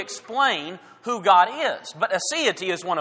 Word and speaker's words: aseity: The word is aseity. We're familiar with the word deity. It certainly means God. Aseity is spelled in a similar way, A-S-I-E-T-Y.